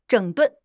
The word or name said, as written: 整顿